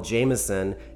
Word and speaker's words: JMSN: You just heard Street Sweeper by a guy called Jameson. If you find him on the internet, that's spelled J-M-S-N. Jameson, I'd love you to tell me a little bit about this track.